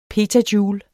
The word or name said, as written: petajoule